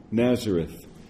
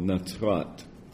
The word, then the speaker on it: Nazareth: Luke 4:14 in the Galilee, and then in 16 he comes to Nazareth, Nazareth or Nazrat, two ways to pronounce it in Hebrew, where he had been brought up.